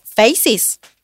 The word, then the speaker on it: Faces: F-A-C-E-S. Una de ellas consultó. Ah, entonces vamos a pronunciarla en inglés decirlo como faces? ¿Es para hacer alguna alusión a distintos rostros y diversidad? No está mal. Las brasileñas respondieron, no, es faces, que en portugués también quiere decir rostros.